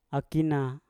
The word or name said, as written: akina